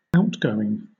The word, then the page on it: outgoing: British English